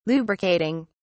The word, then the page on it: lubricating: British English